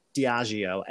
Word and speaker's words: Diageo: So as we sort of pull out into the corporate overlords of Diageo, and Nick, I did my research and that is how you pronounce it.